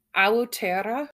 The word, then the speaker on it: Aotearoa: So New Zealand was originally called, I'm going to butcher this, Aotearoa.